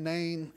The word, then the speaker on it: Nain: The second resurrection was of the widow Nain. I want to make sure I'm saying that right. Nain, N-A-N, Nain, N-A-I-N, Nain.